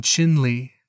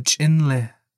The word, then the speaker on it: Chinle: One says they are in Chinle, using the English pronunciation. The other says they are in Chinle, using the Diné pronunciation.